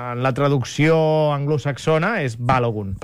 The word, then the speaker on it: Balogun: Balogun, sí, tot i que també hi ha qui diu que el cognom és d'origen nigerià i que llavors la pronunciació seria... – Hauria de ser Balogun.